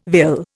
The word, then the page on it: vill: Indian